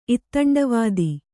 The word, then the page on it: ಇತ್ತಂಡವಾದಿ: ittaṇḍavādi